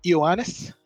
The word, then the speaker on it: Ioannis: Ioannis. It's pronounced with an E, sorry, although it's spelled with an I. I make that mistake.